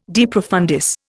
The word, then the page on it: de profundis: Indian